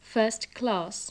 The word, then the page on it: first class: English